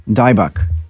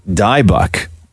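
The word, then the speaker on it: Dybbuk: Come in and tell me how to say this word. It's D-Y-B-B-U-K. How do you say that? – Wait, point to it. Oh. Dybuk. – Dybuk.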